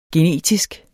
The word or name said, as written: genetisk